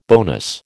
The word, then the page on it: bonus: American English